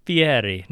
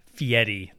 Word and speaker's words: Fieri: If anybody says Fieri, he made that up. Literally, that is Italian, and it is Fieri, not Fieri. That's made up.